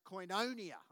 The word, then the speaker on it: koinonia: Now that word fellowship in the original language, I don't want to get too deep with it, but it's a Greek word, which is, I'm not Greek, but it's pronounced koinonia.